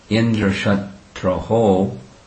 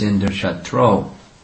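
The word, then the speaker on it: Indrashatru: When he was performing the yagya, he pronounced one syllable wrong. It should have been Indrashatraho, which means one who is Indra's enemy. Instead, it was Indrashatro.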